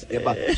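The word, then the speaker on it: Mbappé: Is the E silent, or do you pronounce the E? Is it I just like Mbappe. I like Mbappe. It's Mbappe.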